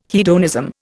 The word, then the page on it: hedonism: Indian